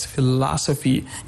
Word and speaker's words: philosophy: You see, you have the word like philosophy. On the second syllable, you have this stress, philosophy.